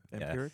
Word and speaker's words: vampiric: And so they're establishing certain motifs that feel like they could be you know, vampiric? – Vampiric, yeah. – Yeah, that's how you say it.